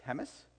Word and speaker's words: James: It's spelt James, but ignore that. It's James.